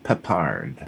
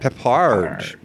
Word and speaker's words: Peppard: And last name pronounced more like Peppard. – Peppard.